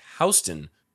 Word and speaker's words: Houston: It is pronounced Houston. Thank you, Wikipedia. Even though it is spelled like the city of Houston, he headed out to New York like an old time pioneer, hoping to find his new life with her.